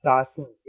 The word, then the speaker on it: Soslan: Well, my name is Soslan Genanson, S-O-S-L-A-N-G-E-M-A-N-S-1. Most people just call me Soslan.